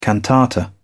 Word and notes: cantata: British English

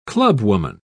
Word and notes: clubwoman: British English

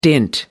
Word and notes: dint: American English

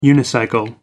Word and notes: unicycle: American English

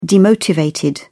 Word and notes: demotivated: British English

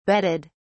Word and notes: bedded: British English

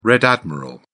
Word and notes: red admiral: British English

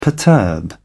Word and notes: perturb: British English

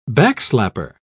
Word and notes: backslapper: British English

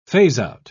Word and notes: phase-out: British English

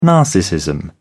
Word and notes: narcissism: British English

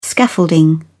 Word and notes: scaffolding: British English

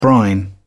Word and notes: brine: British English

